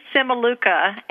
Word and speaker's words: Cimiluca: Okay, well, it's not as bad as it sounds. It's Mary Simaluca, and that's spelled C-I-M-I-L-U-C-A.